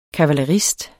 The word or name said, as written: kavalerist